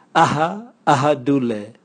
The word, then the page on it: aha ahadule: Raga